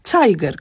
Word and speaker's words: Zeiger: które się pisze przez Z na początku, ale czyta się przez C, Zeiger, które jest, znaczy tyle, co wskazówka.